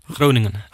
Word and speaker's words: Groningen: But you basically came up the ranks at, is it FC Groningen? – Groningen. – Yeah. Groningen, there we go.